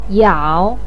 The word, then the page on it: yao: 3